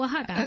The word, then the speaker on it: Oaxaca: It's called Oaxaca. – Okay, but it's spelled W-A-H-A-K-A.